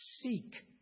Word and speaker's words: Sikh: Sikh people. That's how it's pronounced.